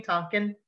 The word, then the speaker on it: Tarkin: Fun fact, if you look at this scene and the cinematography of this scene, nobody is shown from the waist down, because Grand Moff Tonkin, is that how you say his name? Tarkin. Thank you, Tarkin.